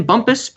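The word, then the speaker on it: Bumpass: I believe you pronounce his last name Bumpus, but it is certainly spelled Bump Ass.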